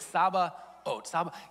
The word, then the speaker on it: Sabaoth: Ready for it? Ready? Sabaoth. Yahweh? Yeah, that doesn't sound right at all. I know. And from someone who just mispronounces things all the time, I wanted to make sure I got it. I'm probably still not doing it, you know, like 100% justice, but it's Sabaoth. Yahweh Sabaot.